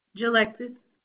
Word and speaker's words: Jalexis: Jalexis. – How you spell that? I'm sorry. – J-A-L-E-X-I-S.